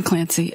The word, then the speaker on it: Clancy: Susan Clancy, S U S A N C L A N C Y.